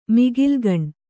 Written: ಮಿಗಿಲ್ಗಣ್

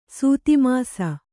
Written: ಸೂತಿಮಾಸ

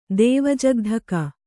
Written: ದೇವಜಗ್ಧಕ

